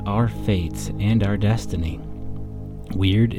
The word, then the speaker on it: wyrd: Weird, pronounced like the English word weird, but spelled W-Y-R-D, is a Norse and Anglo-Saxon word.